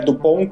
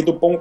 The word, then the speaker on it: Dupont: É Dupont com T e Dupont com D, mas você não pronuncia a letra final, então fica uma coisa.